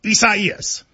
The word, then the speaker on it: Isaias: A lot of people, you know, prayers to everybody involved, but it is Isaias. – Is that how you say it though? – You do.